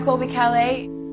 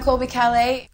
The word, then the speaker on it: Colby Calais: Hvordan uttaler du navnet ditt igjen, Colby? – Hi, I'm Colby Calais. – En gang til. – Hi, I'm Colby Calais. – Colby Calais, sånn var det.